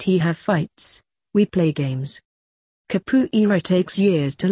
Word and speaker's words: capoeira: I love doing capoeira, pronounced capoeira. It started in my country, Brazil, about 500 years ago.